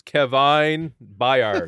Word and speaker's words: Byard: How do you pronounce that? B-Y-A-R-D. – I got it. Hold on. It's Kevin Byard.